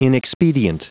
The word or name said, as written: inexpedient